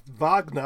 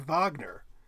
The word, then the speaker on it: Wagner: And I would pronounce the R oddly enough, even though you're not in German, it would be more Wagner. No R at the end, really. But I would pronounce it like Richard Wagner. I would say basically my shibboleth term for the guy is to pronounce the W in a German way and kind of leave it at that.